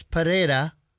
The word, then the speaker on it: Pereira: Versus Alex Pereira is how you pronounce it.